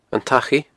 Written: Achaidh

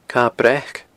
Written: Cadha Breac

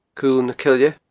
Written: Cul na Coille

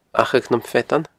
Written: Achadh nam Feadan

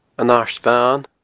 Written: An Aird Bhan

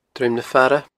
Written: Druim na Faire

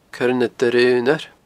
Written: coirenadoireaonair

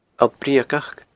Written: Allt Breugach

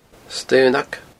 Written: staonag

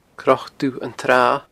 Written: cnocdubhantsratha

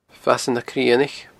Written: Fasadh na Crionaich

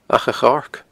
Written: Acha a Choirce

